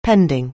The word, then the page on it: pending: anglais